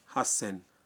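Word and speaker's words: ہس سین (はっせん): 8000 کا تلفظ ہے ہس سین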